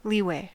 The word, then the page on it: leeway: American English